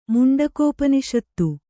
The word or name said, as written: ಮುಂಡಕೋಪನಿಷತ್ತು